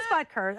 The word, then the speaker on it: Butker: Let's take out your Right, it is Butker.